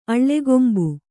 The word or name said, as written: ಅಲಗಂಬು